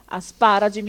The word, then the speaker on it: asparagi: Sdrucciole, l'accento sulla terz'ultima sillaba. Limite, asparagi, mia nonna è del 30, fa 87 anni, non ha ancora imparato a dire asparagi, continua a dire asparagi.